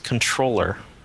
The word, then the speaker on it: comptroller: I'm looking at the Wikipedia entry for comptroller. Apparently, it's supposed to be pronounced controller, which doesn't make sense.